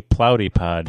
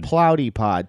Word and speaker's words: Plowdy Pod: Is it like Pilates? Plotty Pod? – No, it's just Plotty Pod. – How do you spell that? – P-L-O-W-D-Y-P-O-D. – Okay. Plotty. Plotty Pod. – Plotty Pod.